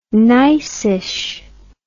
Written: nicish

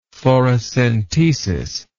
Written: thoracentesis

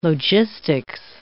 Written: logistics

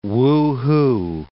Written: Wuhu

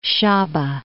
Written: Shaba

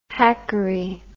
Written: hackery

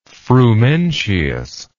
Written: frumentius

